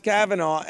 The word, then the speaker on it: Kavanaugh: It's pronounced Kavanaugh.